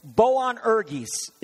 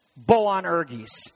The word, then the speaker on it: Boanerges: Boanerges, one of the most difficult words to pronounce, I think, in the Greek New Testament. But it's Boanerges is the word. He called them Boanerges.